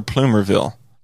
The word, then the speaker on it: Plumerville: Is it Plumerville or Plumerville? – It's Plumerville. They decided to spell it wrong like in the 70s.